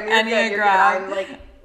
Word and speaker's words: Enneagram: Enneagram.